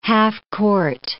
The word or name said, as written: half-court